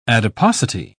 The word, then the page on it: adiposity: British English